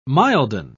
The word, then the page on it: milden: British English